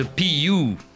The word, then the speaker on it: PU: Just Another Day in the PU. Je l'ai bien prononcé ou pas? – Yeah, parce que c'est un U, donc c'est you. Voilà, tout simplement.